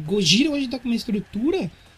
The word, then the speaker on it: Gojira: E, e, tipo assim, a gente fez aquela brincadeirinha que, ah, Gojira é de Godzilla, porque assim, Gojira é a pronúncia de Godzilla em, japonês.